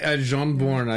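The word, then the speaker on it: Jarnbjorn: Jarnborn, I think. – Is that right? – Yeah. – Okay. – Or Jarnborn. It's with a J, right? – Right. – Yeah. – So it's Jarnborn.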